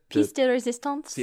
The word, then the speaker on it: pièce de résistance: Pièce de résistance.